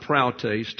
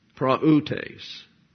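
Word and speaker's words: praoutes (πραΰτης): Now, that's not a diphthong there. It's not praoutes, two syllables. It's three syllables. And therefore, we have the accent on the upsilon, praoutes.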